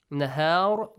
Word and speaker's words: نَهَار: مَا سَكَنَ فِي اللَّيْلِ وَالنَّهَارِ Extend the sound of noon. نَهَار, when you end, pronounce the ra with a heavy sound.